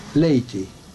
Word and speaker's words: Latey: Wyjątkiem był weteran BBC z czasów wojny, przecinek, utalentowany publicysta Maurice Leighty, pisze się L-A-T-E-Y, przecinek, ale i jego teksty brzmiały w polskich uszach obco i naiwnie, kropka.